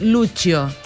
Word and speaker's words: Lucio: Se escribe Lucio, pero es Lucio.